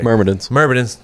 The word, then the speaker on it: Myrmidons: And it's the Myrmidons. I just don't know if I said that right. – Myrmidons. – Yeah. Pretty fucking close, Tony.